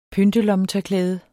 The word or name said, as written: PYNT